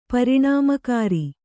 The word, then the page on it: ಪರಿಣಾಮಕಾರಿ: kannada